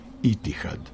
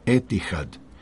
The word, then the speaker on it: Etihad: Citiram: ljubazno vas molimo da primite k znanju da bi ime brenda Etihad Airways trebalo izgovarati kao Etihad, na način kako se izgovara i na engleskom, arapskom i srpskom jeziku.